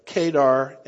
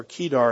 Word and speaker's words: Kedar: And very, very clearly, the context of Kedar, let me get the names right, Kedar and Hazor, there we go, or Kedar and Hazor, depends on which syllable gets the emphasis, as we like to say.